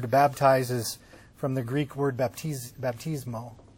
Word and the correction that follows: baptizo: Baptizo. You never say it right.